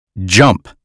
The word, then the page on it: jump: American English